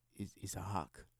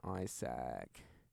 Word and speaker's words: Izak: Everyone thinks because it's written differently, like normally Isaac is spelled I-S-S-A-C or I-S-A-A-C, that my name, spelled I-Z-A-K, is pronounced like Isaac or Isaac or something like, no, it's just Isaac, just literally, it's so much more simple than I-S-S-A-C, it's like Isaac, no, Isaac, with a Z and a K, because that's the noises that they make.